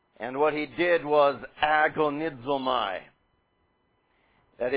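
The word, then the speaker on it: agonizomai (ἀγωνίζομαι): One, if we anglicized it, the first letter of that word being an alpha, pronounced ah, agonizomai.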